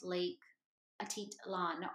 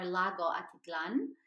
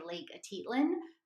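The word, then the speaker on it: Lake Atitlán: Side note, you guys, it is pronounced Lake Atitlan or Lago Atitlan, but the American or the English tendency is to say Lake Atitlan, but it is actually Lake Atitlan.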